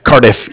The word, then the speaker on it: Cardiff: How do you pronounce it? – Cardiff. Yeah, like Cardiff, Wales.